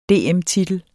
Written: DM-titel

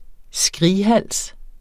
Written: skrighals

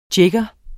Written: JIGGERE

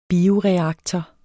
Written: bioreaktor

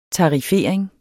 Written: tarifering

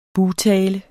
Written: bugtale

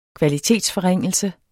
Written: kvalitetsforringelse

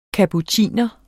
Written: kapuciner

